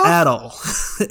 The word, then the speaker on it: Adol: Adol. A-D-O-L.